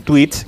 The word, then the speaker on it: Twitch: Luego estaban las campanadas más jóvenes, que esas las protagoniza en Twitch, que es una plataforma eh, online. ¿Cómo se dice esto, David Martos? No, no, muy bien dicho. – Twitch, online.